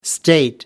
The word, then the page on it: state: American English